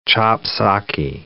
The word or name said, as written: chop-socky